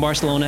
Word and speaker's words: Barcelona: Many English speakers attempt to sound, quote, more Spanish, unquote, by pronouncing the C in Barcelona as TH and saying Barcelona. But in Catalan, the language of Catalonia, where Barcelona is found, the C is pronounced as an S. So Barcelona is the correct pronunciation.